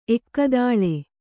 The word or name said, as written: ಏಕತಾಲ